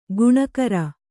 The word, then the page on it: ಗುಣಕಾರ: kannada